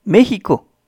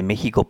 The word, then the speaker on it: México: México es otro ejemplo. La X se pronuncia como J.